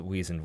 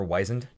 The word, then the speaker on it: wizened: Is that the pronunciation? – Yeah. – Okay. – Oh, you prefer wizened? – No, I don't have a preference. I just probably always assumed it was wizened.